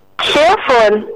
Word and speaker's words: xylophone: No, let me help you with the word. The word is pronounced as xylophone. – Okay. – Yeah, so correct your friends from today. It's xylophone. It has a z sound there.